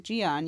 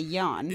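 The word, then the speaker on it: Jian: You pronounce it Gian. You choose to say Jan.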